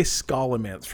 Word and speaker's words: Scholomance: Maybe that's because I tried to add an L. Oh, Scholomance actually sounds far less like scholar. – Awful. I'm going to say Scholomance from now on, regardless of how it is pronounced. I think it's because I keep trying to put an L after the C.